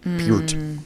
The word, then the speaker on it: Peart: Peart. Neil Peart. See, right, you know, he pronounces it Peart.